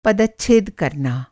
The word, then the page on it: पदच्छेद: Hindi